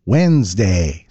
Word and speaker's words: Wednesday: It's midweek Wednesday, which of course we pronounce Wednesday. So why are we spelling it with a random D that nobody invited to the conversation? English is full of words like that. Take Colonel, for example. It's spelled C-O-L-O-N-E-L, but pronounced Colonel.